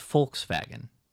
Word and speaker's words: Volkswagen: So it's not Volkswagen, it's Volkswagen. W is a V and a V is an F.